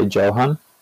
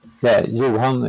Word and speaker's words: Johan: I guess, is the J silent in your name or no? – When I pronounce my name in English, I typically say Johan. – Johan. Okay. I feel like I've been saying Johan and that's not correct. – Yeah. Johan is my name in Swedish and it's typically, it doesn't matter.